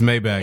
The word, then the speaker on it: Maybach: It's Maybach.